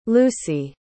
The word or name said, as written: lucy